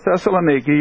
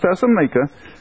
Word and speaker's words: Thessalonica: But when the Jews of Thessalonica, or Thessaloniki, you can either pronounce it either way,